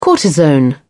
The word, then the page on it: cortisone: British English